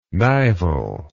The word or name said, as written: nival